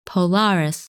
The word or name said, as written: Polaris